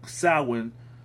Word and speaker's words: Samhain: And it says any crop still in the field of Samhain. It's spelled Sam like Sam Hain, but it's pronounced Samhain.